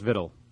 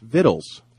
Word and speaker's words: victual: Victual is how you spell it, yes, but it's pronounced vittle. – It's vittles.